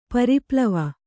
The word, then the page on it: ಪರಿಪ್ಲವ: pari plava